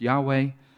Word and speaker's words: Yahweh: Most scholars, there seems to be consensus that it's pronounced Yahweh.